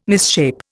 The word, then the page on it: misshape: Indian